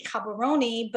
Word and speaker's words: Gaborone: And then I'd say to you, well, I'm from the capital city, Gaborone, but you might not recognize that word because it's spelled with a G and it's said with Gabo. And then it's not a silent E, but then I'll go into this long explanation just of the capital city of Botswana.